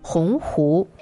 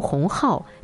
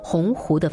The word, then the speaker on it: 鸿鹄: 在北大一百二十周年校庆大会上，校长林建华发言，把“ 鸿鹄” 念成“ 鸿浩”，激起巨大反响。第二天，他主动在北大 BBS 上发了一封道歉信，很抱歉在校庆大会上的致辞中读错了“ 鸿鹄” 的发音。